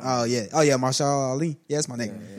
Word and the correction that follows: Mahershala: Mahershala. – Huh? – Mahershala. – Oh, shit. That's how you pronounce his name.